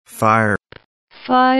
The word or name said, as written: firewall